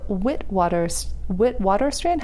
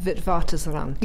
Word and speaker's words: Witwatersrand: Witwatersrand? How do I say that? – Witwatersrand. – Yeah, I had a feeling I was not pronouncing that right.